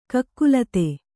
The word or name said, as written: ಕಕಲಾತಿ